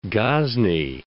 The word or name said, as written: Ghazni